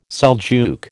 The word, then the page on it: Seljuk: American English